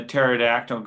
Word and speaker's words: pterodactyl: Uh, why can't you hear um, a pterodactyl going to the bathroom? I don't know why. – Because the P is silent.